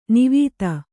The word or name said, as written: ನಿವಾತ